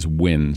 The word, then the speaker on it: Nguyen: I mean, somebody who was playing today, like Lee Wynn for LAFC, his name is not phonetically spelled N-G-U-Y-E-N. – And yet it's Win.